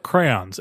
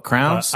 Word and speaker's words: crayons: I like that you pronounce crayons. – Crayons. – Yeah. I like, because people down here call it crowns.